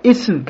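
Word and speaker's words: исм (اسم): Допустим, слово «ифм» – это значит «грех», а слово «исм», когда язык за зубами, это значит «имя».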